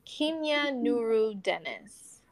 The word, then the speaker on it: Kimya Nuru Dennis: So it's pronounced with a long E and then Nuru. Kimya Nuru. – Kimya Nuru Dennis.